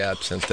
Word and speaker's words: absinthe: How do you say that? Absinthe? – Yeah, it's absinthe.